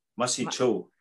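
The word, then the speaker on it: mahsi cho: We say masi. Masi cho. – Masi cho. – Masi. Yeah. Masi is thank you.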